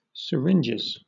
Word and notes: syringes: British English

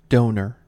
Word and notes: donor: American English

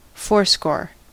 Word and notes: fourscore: American English

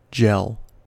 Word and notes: gel: American English

